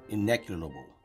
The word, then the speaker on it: ineluctable: Say it again. – Ineluctable. – No. – Ineluctable. – You're jumping a syllable. – Ineluctable. – You added another syllable. – Motherfucker. – You're doing it how I would do it.